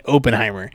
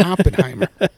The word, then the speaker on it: Oppenheimer: The motherfucker was saying Oppenheimer. – Yeah. – So. – That's Oppenheimer. – It's Oppenheimer.